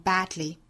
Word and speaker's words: badly: 好，后面这个 badly 我们再来讲一下啊，在英音之中这个词一般会读成 badly 会有一个舌边爆破，因为里面包含了一个的和一个舌侧音 a 的组合，这个音呢一般会发成 dl。